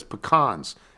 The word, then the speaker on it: pecans: By the way, I, everyone I know in North Carolina says pecans, as in James Caan.